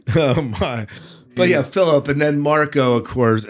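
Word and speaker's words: Filip: Philip, I think, is the way they pronounce it in the book. – Yeah. – Philip in the. – Okay. – So it's not that. I know you're a French expert, but it's not French this time.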